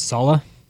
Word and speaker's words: Saleh: Robert Saleh.